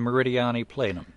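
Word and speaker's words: Meridiani Planum: And forgive if, and the Martians who might be listening in 1,000 years, please forgive me any mispronunciations. This is the Gusev Crater and the Meridiani Plenum. – Well, your pronunciations were perfect.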